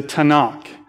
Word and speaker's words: Tanakh: And what that Jewish Bible would be called is a Tanakh. And that's just a way they pronounce this acronym, which is TNK.